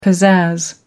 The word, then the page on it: pizzazz: American English